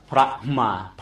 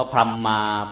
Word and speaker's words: พรหม: ามีสระไหมเมื่อมันมีสระเองแล้วมันจำเป็นจะต้องไปสะกดสระอื่นไหมไม่จำเป็นดังนั้นอ่านพรหมไม่ได้เด็ดขาดยิ่งพรหมยิ่งแล้วใหญ่เลยยังไม่ถูกบาลีเลยพรหมมายิ่งไปกันใหญ่เลยพรหมมายังพอถูกถ้าจะอ่านให้ถูกเขาอ่านว่าพระมาพระมาพระมาพระมาบังเอิญว่าเราฟังโบราณเขาไม่ทันแล้วก็อ่านว่าพรหมมา